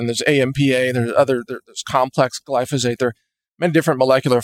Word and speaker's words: glyphosate: And in case you don't know how to spell that, it's glyphosate, glyphosate. It's similar to phosphate because that's actually, believe it or not, that's part of the molecule, but it's not the same as phosphate. It's glyphosate.